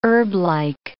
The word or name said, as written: herblike